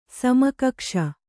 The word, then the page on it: ಸಮಕಕ್ಷ: sama kakṣa